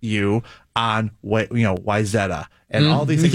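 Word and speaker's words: Wayzata: The Wyzetta one drives me nuts because there's no reason that should be pronounced that way when you look at how it's spelled.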